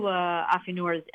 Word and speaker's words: affineur: So let's see, in the French, is it affineur? A-F-F-I-N-E-U-R? – Yep, affineur practices affinage,